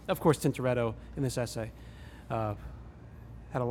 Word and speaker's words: Tintoretto: How do you say his name? – Tintoretto. – Tintoretto? Yeah. Do you have to say it with the accent like that? – I didn't realize I did it.